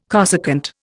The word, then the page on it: cosecant: Indian